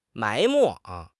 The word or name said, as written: 埋没